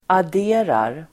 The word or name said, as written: adderar